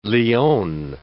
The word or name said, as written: leone